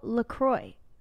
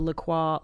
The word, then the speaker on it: LaCroix: Nobody knows how to pronounce this drink in America. – I think LaCroix, that's the French way, maybe. – But then someone said it's LaCroix because there's an island that it's pronounced LaCroix.